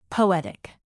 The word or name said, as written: poetic